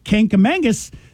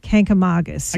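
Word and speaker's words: Kancamagus: Like Kankamangus, there is no N in no. – Kanka, and it's Kankamon. – Kankamagus.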